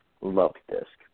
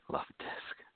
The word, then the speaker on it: Luvdisc: L-U-V-D-I-S-C. Love Disc. – Love Disc.